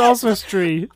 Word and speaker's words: Oswestry: Is that how you say it? Austria. – It's Oswestry. The Are place where kidding? – I live. – That's how you spell that? Oswestry? – O-S-W, Oz, Westry.